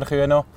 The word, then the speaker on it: Kühne: Raphael Kuhnen oder Kühne, wie man glaube sagt. Wie, wie, spricht man den Namen genau richtig aus im Wallis? Äh, Kühne, ja. Im Wallis äh, sagen wir eigentlich immer Kühne.